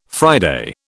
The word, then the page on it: Friday: British English